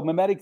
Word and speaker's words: mimetic: So mimetic, did I say that right? Mimetic. – You did.